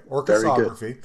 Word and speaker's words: Orchesography: So, okay, Orchesography. Am I saying it right? Okay, Orchesography. – Very good.